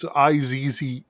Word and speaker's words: Izze: And I don't know if I'm pronouncing Izzy right. It's Izze grapefruit soda.